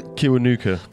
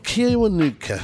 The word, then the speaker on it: Kiwanuka: And then the final track, Little Simz featuring Michael Kiwanuka. Have I said that right? Kiwanuka. Kiwanuka. Thank you.